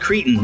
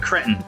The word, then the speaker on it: Cretan: It's pronounced Cretan, not Cretan.